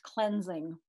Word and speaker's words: Klinzing: Yeah, I always tell people it's like Ajax Klinzing.